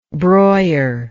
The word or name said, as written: Breuer